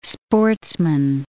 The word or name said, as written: sportsman